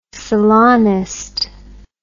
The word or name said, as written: salonist